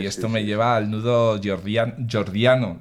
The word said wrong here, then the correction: gordiano: ¿lo he dicho bien? Gordiano, sí, eso.